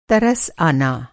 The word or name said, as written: तरस